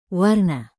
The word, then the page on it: warna: Indonesian